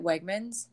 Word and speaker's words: Wegmans: Is that how you say it? Wegmans? – Yes.